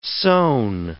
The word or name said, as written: sewn